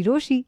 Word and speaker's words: Rochie: मेरा नाम है मिर्ची रोशी. नाम की स्पेलिंग है R-O-C-H-I-E.